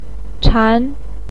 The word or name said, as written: chan2